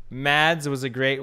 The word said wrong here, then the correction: Mads: I sorry, it's not Mads. It's Mass. Mass. Mass. You don't pronounce the D.